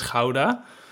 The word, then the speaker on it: Gouda: But you probably know Gouda, which we pronounce as Gouda.